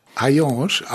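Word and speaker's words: Heillange: Vielleicht zunächst der Ort, der heißt Ayange, geschrieben H-A-Y-A-N-G, das, so heißt der reale Ort. Der Ort im Roman heißt Ayange auch, aber geschrieben H-E-I-L-L und A-N-G-E. – A-N-G-E. Es spricht sich beides gleich aus.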